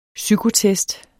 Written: psykotest

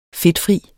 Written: fedtfri

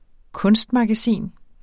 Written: kunstmagasin

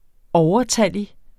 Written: overtallig

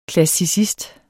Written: klassicist

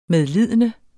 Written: medlidende

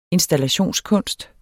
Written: installationskunst

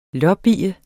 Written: lobbye

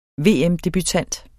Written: VM-debutant

